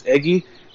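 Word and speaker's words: Eguy: Is Eggy like egg? – I'm looking at the Lake Elsinore pronunciations. It's Eggy.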